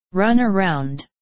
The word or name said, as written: run around